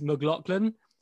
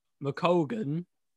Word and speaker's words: McColgan: I thought this was pronounced Liz McLaughlin, but I actually think it's pronounced McColgan. So there's Liz McColgan and Eilish McColgan.